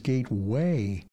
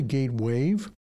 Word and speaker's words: Gatewave: We've learned some of you have been hearing our name as Gateway, ending in W-A-Y. Now, that may mean we haven't been pronouncing it clearly enough. That would fall on my shoulders. It's really Gatewave, G-A-T-E-W-A-V-E, like a sound wave or ocean wave. We're Gatewave.